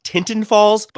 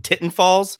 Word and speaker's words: Tinton Falls: From the Jug Handle Brewing Company out of, it should be pronounced Tinton Falls, but it's right where I live and people call it Titten Falls.